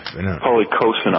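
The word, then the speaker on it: policosanol: Polycosanol.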